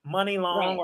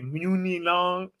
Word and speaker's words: Muni Long: Like, Money Long, or how some people mispronounce her name and say Muni Long, right?